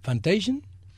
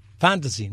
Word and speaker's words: Fantasia: Y en el 46, ¿Fantasian? Fantasy, ¿no es Fantasy? Ah, Fantasia. Fantasía se dice así.